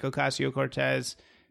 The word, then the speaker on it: Ocasio-Cortez: And I feel like there's a risk with somebody like Ocasio-Cortez. Am I saying this? – Yeah, you're right? – Saying it right.